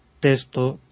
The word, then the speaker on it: texto: Porque se pronunciaba, ya desde hacía siglos, texto, textura, etcétera. Eso es lo mejor que podemos decir. Y después, en pronunciaciones muy enfáticas o muy esmeradas, podemos ir ya a una K y una S, o una G y una S. Texto, texto, pero eso es un poquito complicado.